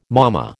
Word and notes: mama: American English

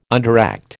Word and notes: underact: American English

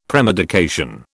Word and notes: premedication: British English

